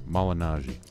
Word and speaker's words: Malignaggi: We're talking, we've talked the first segment there about the McGregor issues in his camp Paulie Imaginaro, uh, Malinaro. Ah, screw up his name. How do you say it again? Malignaggi. Malignaggi, who, who's been on my show before, part of the family here.